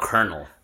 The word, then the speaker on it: colonel: Like you don't have kernel. – Kernel. Yeah. Okay. C O L E O eh, C O C O L O N E L.